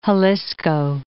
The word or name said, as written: Jalisco